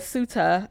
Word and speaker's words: Ceuta: I looked up how to pronounce the word because I was saying it wrong earlier. But Ceuta on Thursday.